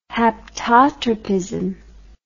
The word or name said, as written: haptotropism